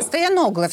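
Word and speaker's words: Стояногло: Ну вот вам придется отвечать сразу за две позиции тех, кто живет в Москве и голосует за генерального прокурора бывшего Александра Стояноглу, и за тех, Стояногла. – Кто... Стояногла. Стояногла все-таки, да, простите, пожалуйста, Стояногла, буду правильно говорить.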